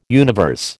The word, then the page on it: universe: American English